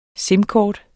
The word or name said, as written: sim-kort